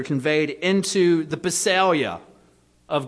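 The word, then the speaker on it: basileia: The Greek word translated kingdom here is the word basalia. Basalia. It's spelled B-A-S-I-L-E-I-A. B-A-S-I-L-E-I-A.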